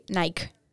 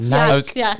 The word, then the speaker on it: Nike: How do you pronounce Nike or as the Europeans say? – Let me think. No, we just say Nike. – Yeah, that's what I thought. – Nike.